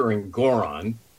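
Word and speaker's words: Engoron: This is a guy, Arthur Elgaron, whose last name, by the way, rhymes with moron,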